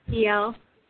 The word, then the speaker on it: Dielle: It's DL.